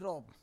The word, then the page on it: rob: Roon